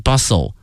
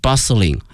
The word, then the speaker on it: bustling: bustling 这个词 b-u-s-t-l-i-n-g，记下来这个词 bustling 表示说这个街道特别的繁华，但是有一点哈，这里面这个 t 这个字母 t 是不发音的，叫 bustle bustling 啊，这是它发音上的一个细节哈，希望同学们注意一下 bustling street。